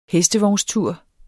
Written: hestevognstur

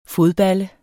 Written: fodballe

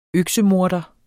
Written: øksemorder

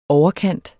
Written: overkant

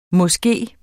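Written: mås

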